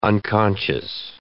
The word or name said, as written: unconscious